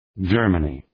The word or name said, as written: Germany